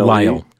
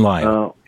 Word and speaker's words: Lisle: The author's name is Jason, I'm not certain of the pronunciation, L-I-S-L-E. – Lyle, Jason Lyle.